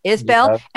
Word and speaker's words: Isbell: Am I saying this right? Isbell or Ibell? Jason Ibell. Isbell. – Isbell.